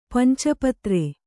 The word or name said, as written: ಪಂಚಪತ್ರ